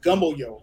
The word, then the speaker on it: Gumboyo: Okay, well, it's actually pronounced Gumboyo. So think of like Gumboyo.